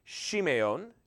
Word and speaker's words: Shimeon: The second one is Simeon, which would have been pronounced Shimeon. It's an SH sound at the beginning, Shimeon.